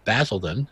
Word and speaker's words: Basildon: Basildon. Is that correct? Did I pronounce that correctly? – Basildon's good, yes.